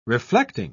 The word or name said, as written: reflecting